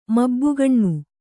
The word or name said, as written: ಮಬ್ಬುಗಣ್ಣು